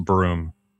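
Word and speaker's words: Bruttenholm: Remember, it's pronounced broom.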